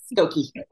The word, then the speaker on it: Skokie: Yes, Skokie.